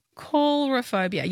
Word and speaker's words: coulrophobia: Well, C-O-U-L. I'm saying coulrophobia. Yeah, coulrophobia. – like colour on the air.